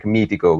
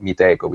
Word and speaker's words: Meetecho: we were actually meaning to have it pronounced like Mitico, which means awesome in Italian. But unfortunately, we were too ignorant at the time to realize that adding the H there actually made it make it sound like Miteco, which is how most people pronounce it right now.